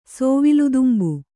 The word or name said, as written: ಸೋವಿಲುದುಂಬು